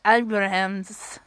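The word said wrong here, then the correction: Abrahantes: I'm butchering his last name. I'm sorry. I do follow him on Instagram. Wait, let's solve this. Let me make sure I say this right, because I'm trying to remember. Yeah, it's Alex Abrahantis. Abrahantis. Yeah.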